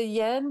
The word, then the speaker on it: Yanne: Y-A-N-N-E. – And it is pronounced? – Yan.